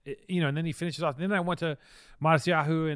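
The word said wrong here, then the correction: Matsuhisa: and then I went to, not Matsuyahu. how do you say it? – Matsuhisu. – Matsuhisu.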